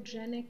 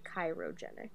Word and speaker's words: cryogenic: Cryogenic freezing. – It's cryogenic, and I always want to say chirogenic.